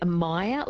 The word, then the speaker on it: Meyer: Elle has texted through and she's asked the question, about myria? She says, does the iron issue apply to myria as well? – So maybe a Meyer lemon, so M-E-Y-E-R. – Yeah. That's it, yes. Excuse my bad pronunciation of that.